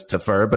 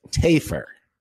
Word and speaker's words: Tafur: And yet we went through the entire segment calling him Vic Tafer, which, based on the spelling of his name, T-A-F-U-R, we wrongly inferred, nailed it, that the pronunciation of the name was Tafer, but it is, in fact, as was corrected by Vic himself in a private message after the show, Tafer.